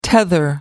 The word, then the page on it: tether: American English